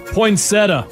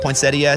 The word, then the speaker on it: poinsettia: Poinsettia. – Except. Correct. Poinsettia is how it's spelled, but I say poinsettia.